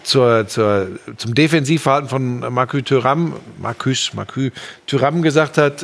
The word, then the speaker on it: Marcus Thuram: Marcus Thuram, äh, ja, du, ich bin immer vorsichtig. Der Schmiso erzählt mir immer Sachen zur Aussprache. – Das war perfekt ausgesprochen.